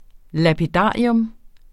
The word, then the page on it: lapidarium: [ lapiˈdɑˀjɔm ]